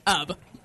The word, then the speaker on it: Ub: It's pronounced Ub.